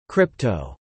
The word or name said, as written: crypto